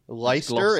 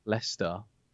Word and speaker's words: Leicester: Leicester, isn't it? Leicester as well? – Leicester.